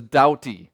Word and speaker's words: Doughty: It is not Dougherty. There's no R in there. That's spelled correctly. my last name is Doughty. So there's a couple ways to remember that. I doubt that it's Doughty. That's one way. Another way is Rowdy Dowdies. That's what we usually tell people when we're introducing our kids and everybody that we are the Rowdy Dowdies.